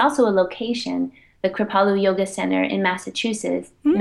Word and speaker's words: Kripalu: Kripalu.